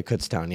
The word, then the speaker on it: Kutztown: Yeah, Kutztown. Yeah, that's how you pronounce it.